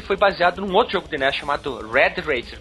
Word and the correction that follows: Rad Racer: pronunciei certo? – Rad Racer. Exatamente.